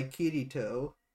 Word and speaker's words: Kirito: So so K I R I T O. So like Kirito.